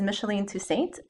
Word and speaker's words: Micheline Toussaint: And here with me is Micheline Toussaint, an oncology therapist in Fairfax, Virginia.